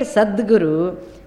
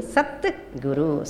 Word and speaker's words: Sadguru: So therefore, even though you write Sadguru, when you are saying that meaning, Satguru, Sadguru, and I will pronounce.